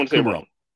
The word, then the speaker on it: Kumerow: Yeah, Kumerow.